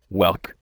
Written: whelk

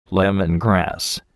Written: lemongrass

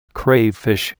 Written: crayfish

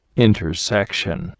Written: intersection

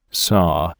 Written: saw

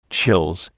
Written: chills

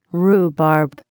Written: rhubarb

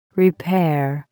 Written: repair

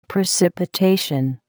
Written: precipitation

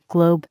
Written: globe

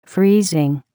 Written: freezing

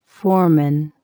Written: foreman

